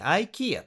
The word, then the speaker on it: IKEA: 日本語では IKEA って言いますが、英語だとですね、 IKEA という発音になります。 IKEA というふうには発音できないんですね。